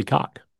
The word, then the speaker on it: Koch: K-O-C-H. Koch? – I'm pretty sure it's not what you said. – Yeah. Well, I don't know. That's how, like, the former closer used to, for the A's used to pronounce it, Billy Cock.